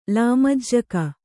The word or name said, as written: ಲಾಮಜ್ಜಕ